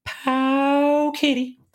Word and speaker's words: Pow Kitty: But now there are so many, like the Embernic, the Pau Kitty. I need to say it correctly. Pow Kitty. That's the way I was supposed to say it.